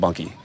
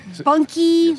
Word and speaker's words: Bunkie: I was born in Baton Rouge and grew up in a little town called Bunky. – Bunky? – Yeah, look that one up. – I will. How do you spell it? B-U-N? – B-U-N-K-I-E. – Bunky, yes, Louisiana.